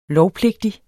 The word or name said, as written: lovpligtig